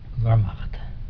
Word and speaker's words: Wehrmacht: Were there any differences between, okay, here comes another one I'm going to mispronounce, the Wehrmacht guards and the SS? – Wehrmacht.